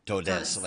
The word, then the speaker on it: todes: If todos, todas, how do I pronounce toad XS? – No, you can't. You can't pronounce it. It's just written. – You say todos. You say todos. – Todes, like with an E, right? Todes? – Yes.